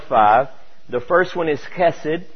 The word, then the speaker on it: chesed: So I usually spell it C-H-E-S-E-D, chesed.